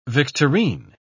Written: victorine

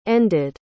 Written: ended

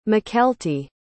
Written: mykelti

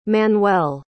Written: Manuel

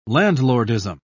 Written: landlordism

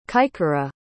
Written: kaikara

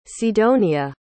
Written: sidonia

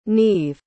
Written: Nieve

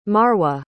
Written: marwa

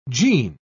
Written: jean

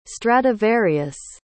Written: stradivarius

